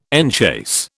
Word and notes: enchase: British English

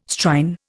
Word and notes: Strine: Indian

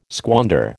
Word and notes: squander: American English

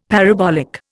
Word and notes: parabolic: Indian

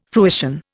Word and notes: fruition: Indian

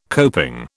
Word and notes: coping: British English

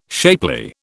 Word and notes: shapely: British English

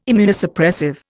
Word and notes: Immunosuppressive: Indian

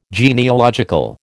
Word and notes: genealogical: American English

